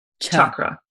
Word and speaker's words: chakra: I mean, tomato, tomato, but I've been like told by people with Sanskrit backgrounds that the proper pronunciation is the harder chakra. – Gotcha. – Not a chakra.